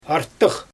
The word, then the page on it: artyx: Xyzyl